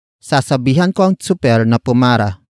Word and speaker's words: sasabihan: Word for word, it's sasabihan ko ang super na pumara.